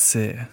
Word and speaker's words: Sear: I have got with me Juliette Sear, who is the, did I say that right? – Yeah. – Yeah, okay, good. – You didn't put an S on the end like most people do.